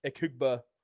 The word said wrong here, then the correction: Egbuka: am I saying that right? – Egbuka.